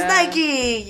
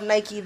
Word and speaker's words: Nike: It is Nike. Yes, the wings of Nike, the goddess of victory. – Yes, as my art history professor would pronounce it, Nike.